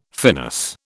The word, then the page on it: finis: British English